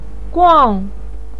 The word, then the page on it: guang: 4